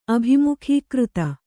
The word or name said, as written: ಅಭಿಮುಖೀಕೃತ